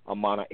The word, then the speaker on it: Amaana: It is Moorish Talk Live 100, And all you have to do is just type my name into Google, which is Tahaka Amana LB. That's T-A-R-H-A-K-A. Amana is spelled A-M-A-A-N-A, and then E-L, and then B-E-Y.